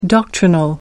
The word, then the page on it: doctrinal: American English